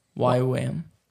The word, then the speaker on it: YWAM: How do you say that? – YWAM. – YWAM. Yeah.